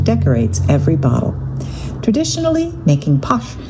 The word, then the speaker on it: Pox: One of the first commercial bottlings of Pox, I'm seeing it written P-O-X, but they pronounce it Posh, available in the United States.